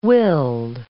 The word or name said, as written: willed